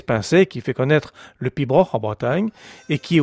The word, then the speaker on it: piobaireachd: Alors, le pibroch, on va le voir, c'est vraiment classique, puisque ça s'écrit P-I-O-B-A-I-R-E-A-C-H-D, et ça se prononce tout simplement pibroch.